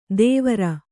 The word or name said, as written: ದೇವರ